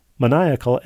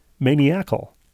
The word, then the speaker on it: maniacal: For a long time, longer than I'd like to admit, I mispronounced the word maniacal as maniacal. – I can see that. – She writes it as maniac plus al, which I like.